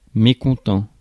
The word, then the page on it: mécontent: France; [me.kɔ̃.tɑ̃]